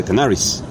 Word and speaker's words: Canaries: No, de Canaries, bien pronunciado, porque si no voy a malgarejar.